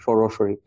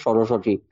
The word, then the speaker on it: Saraswati: So, and the reason Saraswati does not do anything for you is because you mispronounce her name. Because rather than pronounce her name the way it is, you have even made her name into like Obinondon, Saraswati. It's just Saraswati.